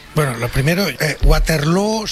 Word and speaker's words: Waterloo: Pues no lo es, desde luego, pero Aba ha hecho mucho daño en la pronunciación de Waterloo, que se pronuncia igual que Feijóo.